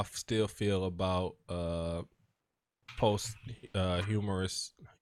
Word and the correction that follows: posthumous: post. Is that how you say it? Yeah. I mean, after people die, posthumous.